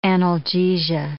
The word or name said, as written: analgesia